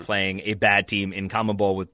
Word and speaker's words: CONMEBOL: So is it CONMEBOL? – CONMEBOL. Yeah. All right.